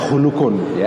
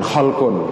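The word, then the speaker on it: hulukun (خُلُق): Bacanya hulukun ya Jadi bukan holkun Karena dua kata dalam bahasa Arab ini saling berhubungan tapi maknanya beda. Kalau dibaca holkun artinya adalah bentuk lahir ini ya, bentuk fisik kita.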